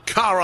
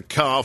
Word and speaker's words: Kahr: Proud sponsor of this segment, Kahr Firearms Group. That's spelled K-A-H-R. Car Arms, Magnum Research that provide us the Desert Eagle, and Auto Ordnance, the modern Tommy gun. Together, that's the Car Firearms Group, a family of brands defending your family's freedom.